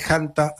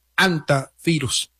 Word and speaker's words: hantavirus: Se podría pronunciar también con la H muda, por supuesto, y también es válida la pronunciación haciendo el énfasis en la primera sílaba con la letra J de Hanta, aunque también es válida la forma antivirus.